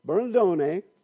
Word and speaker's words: Bernardone: Giovanni di Pietro Bernardone, you got to say it like that, it's an Italian name, Bernardone, thank you for laughing, Jared, was born around 1181, around 81.